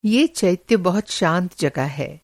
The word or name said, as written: चैत्य